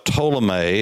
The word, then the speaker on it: Ptolemy: Lebanon, that area was under Seleucus, a man by the name of Ptolemy. It's spelled P T O L E M Y, uh, Ptolemy.